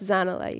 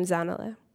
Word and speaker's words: Zanella: So I look back at Mrs. Niels, and I'm like, oh, actually, it's pronounced Zanella. You pronounce my name Zanella.